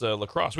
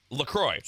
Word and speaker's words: LaCroix: And he goes on to say it's pronounced Lacrosse, which is great. That's wonderful. If you go to the official LaCroix website, they will tell you it is pronounced LaCroix.